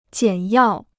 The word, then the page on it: 简要: jiǎn yào